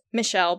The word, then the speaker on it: Michel: His name is Michel. It's Michel, but it's Michael. Like Michael, I guess. But yeah, Michael. But it is pronounced Michel.